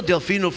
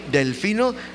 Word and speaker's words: Delfino: You can address it to Pastor Delfino Garcia Jr. Let me spell Delfino for you. D-E-L-F-I-N-O. That's my name, Delfino Garcia, G-A-R-C-I-A Jr. Praise God.